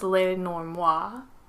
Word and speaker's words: Lenormand: Now we get into the Lenormand or Lenormand, however you pronounce it. In French, I believe it's Lenormand.